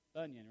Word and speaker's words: Bunyan: Bunyan? Bunyan, right? Like Paul Bunyan.